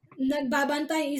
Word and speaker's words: nagbabantay: I have trouble with nagbabantay. Nagbabantay. Is that right? – Sounds... Okay. You need to practice pronunciation. Okay. – Yeah, that's my problem a lot. – Yes. Nagbabantay is difficult.